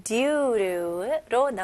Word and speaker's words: due to: 여기서는 due, 그 다음에 to이지만 원어민들의 이 to 단어는 조금 이렇게 약화시켜가지고 너무 to, 센 t 발음보다는 약화시켜서 루, due to 이런 식으로 발음합니다.